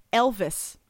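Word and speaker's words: Elwes: The correct pronunciation, according to Carrie himself, is Elvis, but with a W.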